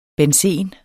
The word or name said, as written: benzen